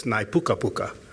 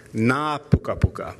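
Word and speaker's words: ngā pukapuka: In Māori you add N-G-A to the beginning, so it's nai puka puka. But the A has a bar over it to say that it's a long A. So it's na puka puka.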